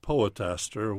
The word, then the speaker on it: poetaster: but a poetaster, which rhymes with disaster.